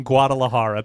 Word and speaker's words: Guadalajara: No, you gotta not pronounce the G. – Guadalajara? – Yeah.